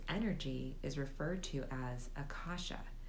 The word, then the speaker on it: Akashic: And I should say that you might hear it referred to as the Akashic Records, but I do pronounce it Akasha.